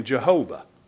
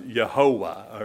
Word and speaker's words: Jehovah: And in our English Bible, whenever you see that Lord in caps, that's what we have come to pronounce in our English language, the name Jehovah. And that's a rough transliteration of the Yahweh in the scripture without the vowel points or what some pronounce Yehovah or Jehovah.